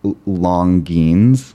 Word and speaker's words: Longines: Because it's spelled Longines, like L-O-N-G-I-N-E-S. But it's pronounced Longines.